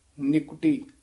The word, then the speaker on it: निकटी: निकटी देह, हां जी निकटी नन्ने दी सिहारी है ना, निकटी इस तरह उच्चारण करना,